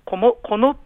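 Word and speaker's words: 籠: それからとですね、京都の宮津市というあのいわゆるあの、天の橋立てで有名なの宮津市に、竹冠なる竜と書いてあのこのというふうに読むんですけども、この神社というところでは、傘みくじとか扇みくじというのが出てます。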